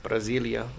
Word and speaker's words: Brasilia: In Brasilia, Brazil. Is that how you say it? – Yeah. Brasilia, Brazil.